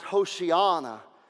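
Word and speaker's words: Hoshiana (הושענא): In its original context, when we see the word Hosanna in the Hebrew, it is pronounced Hoshiana.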